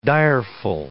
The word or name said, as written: direful